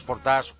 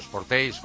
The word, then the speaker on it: Sportage: Este nuevo Sportage, o Sportage, como quieras pronunciarlo, en inglés o en francés,